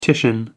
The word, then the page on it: titian: American English